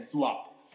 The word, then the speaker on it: Zwap: Però lascio a te la, la tua presentazione, da dove vieni, cosa, ti, cosa, hai fatto nella vita fino ad oggi e che cos'è Zwap. Scritto doppia, eh, ZWAP, giusto? Detto bene? – Esatto, esattamente.